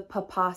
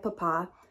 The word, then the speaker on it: Papin: This is the case of the Papin sisters. Now, it is spelled P-A-P-I-N, but in French, it is pronounced Papin. I'm probably pronouncing it wrong, but I did look it up and it's like, it's Papa.